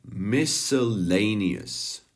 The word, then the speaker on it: miscellaneous: Vyslovuje se miscellaneous. Miscellaneous.